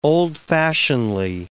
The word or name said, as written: old-fashionedly